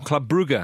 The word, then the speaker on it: Club Brugge: Club Brugge, or Club Brugge, as it's actually pronounced, Andy.